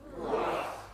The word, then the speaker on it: Ruach: Ruach. – At home, drop that in the comments. It's spelled R-U-A-C-H. R-U-A-C-H, Ruach.